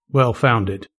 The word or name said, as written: well founded